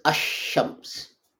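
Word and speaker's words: ashams (الشمس): Now, if you add al shams, that becomes ashams. Although lam is written, in pronunciation it's dropped.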